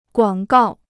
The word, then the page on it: 广告: guǎng gào